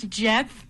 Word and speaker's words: Geoff: I mean, you were very close on the most difficult name, but the first name of Neal, it's not Geoff. It's spelled Geoff. – It's Jeff.